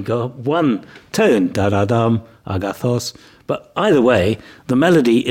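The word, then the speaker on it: agathos (ἀγαθός): So if you say the word good in ancient Greek, it's agathos, but actually the accent is on the last element of that word, so your voice would go agathos. You hear my voice rising in pitch at the last syllable, agathos.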